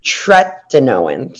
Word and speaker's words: tretinoin: Tretinoin. So there's not an N before the T.